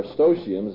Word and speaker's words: Eustochium: In a letter to Eustochium, or Eustochium as some pronounce it, who's an aristocratic young lady, Jerome sets forth his ideas on marriage.